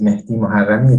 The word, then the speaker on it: Mehdi Moharami: My name is Mehdi Moharami. It's the actual pronunciation if you want to know.